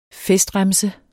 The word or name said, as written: FESTREMSER